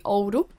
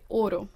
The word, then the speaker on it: ouro: Well, so if you are from the north, where I'm from, you would pronounce the U quite strongly, so you'd say ouro. But if you'd be from the south, you'd pronounce it ouro.